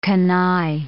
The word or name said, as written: canaille